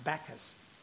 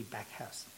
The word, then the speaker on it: Backhouse: Sir Edmund insisted that his surname should be pronounced Bacchus, as in the classical god of wine and merrymaking, but other family members say it's simply Backhouse.